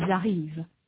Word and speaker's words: arrivent: Note in particular that the S of tu arrives is not pronounced, nor is the final NT of ils arrivent.